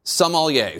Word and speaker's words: sommelier: First of all, Jake, I'll say I'm very impressed with your pronunciation of sommelier. It's not an easy word, so I'm guessing you've spent a little time in wine lists and restaurants.